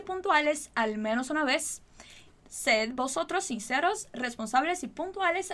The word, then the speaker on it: sed: E daí nós temos sed. Cuidado para não falar sede, ok? Que você está com sede e precisa tomar água. Então, sed, sed, sed, ok? Muito cuidado com essa pronúncia.